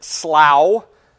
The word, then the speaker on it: Slough: I'm going to pronounce it slough, like plow or cow.